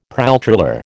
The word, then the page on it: pralltriller: American English